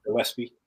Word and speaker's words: Gillespie: Gillespie. Yes. – Okay. – Yes. Well, they both have pretty much the same height, weight, frame. But of course, one is more of a box safety. Gillespie is more of he's more of your box safety.